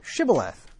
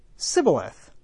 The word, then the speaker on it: Shibboleth: Then said they unto him, say now, Shibboleth. And he said, Sibboleth. Sibboleth. He can't pronounce the SH.